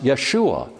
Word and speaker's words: Yeshua: And in Hebrew, that's Yehoshua. And after the time of the Babylonian captivity, it's pronounced Yeshua.